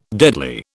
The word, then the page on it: deadly: British English